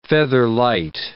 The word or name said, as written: featherlight